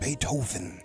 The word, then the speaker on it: Beethoven: Have we been pronouncing his name wrong? – No, Beats the hell out of me. – It's definitely Beethoven, right? – Beethoven.